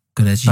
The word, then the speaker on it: Goraguer: So this Alan Goraguer, how do you say it? Goraguer? – That's him. – Yeah. – I thought it sounded a bit like the French version of Gallagher.